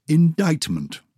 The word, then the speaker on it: indictment: C. Indictment. Muscle. Silent C.